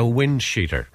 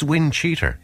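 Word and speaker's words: windcheater: So I was looking for a wind sheeter, right? I always said wind sheeter. – No, it's cheater if you want to. – It's wind cheater.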